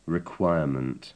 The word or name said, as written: requirement